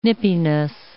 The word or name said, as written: nippiness